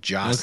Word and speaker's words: Gyasi: It's G-Y-A-S-I, but it's pronounced Jossie.